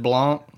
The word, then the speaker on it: Blanc: I've always said Blanc. – Mel Blanc, that's what I thought too. Mel Blanc. – But as a kid, I probably said Blank.